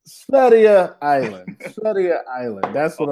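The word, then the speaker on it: Sverige: Severge Island. I know Severge is not how you say it in Swedish, but that's how I pronounce it.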